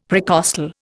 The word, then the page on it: precostal: Indian